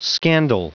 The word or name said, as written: scandal